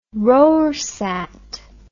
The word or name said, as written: rorsat